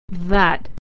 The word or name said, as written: that